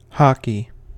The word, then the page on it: hockey: American English